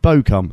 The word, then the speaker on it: Bochum: The band come from Bochum, pronounced Bochum, thank you, which is the Ruhr area of northwest Germany, so beloved by Bomber Command Pascal Briggs, the main man.